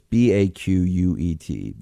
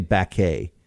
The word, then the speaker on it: Baquet: It's Baquet. We'll say Baquet, because that's the way in French it would be.